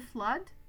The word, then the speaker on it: flood: A flood, une inondation, is the noun. The double O is pronounced a, as in blood, le sang.